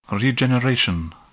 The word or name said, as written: regeneration